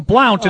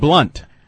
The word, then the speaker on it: Blount: People who listen to Fred call him Blount and think that's how it's pronounced.